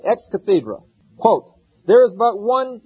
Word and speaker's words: ex cathedra: You know, according to the Catholic Church, when a pope speaks ex cathedra, or ex cathedra, as some pronounce it, that is an infallible statement.